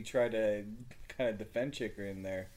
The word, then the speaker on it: Chychrun: That's the best defenseman in the draft, Jacob Chychrun. – Chychrun.